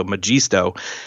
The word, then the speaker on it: Magisto: But what this means is that this tool called Magisto at least that's I'm pretty sure how you pr- pronounce it. It's Magic, but then Esto, so Magisto.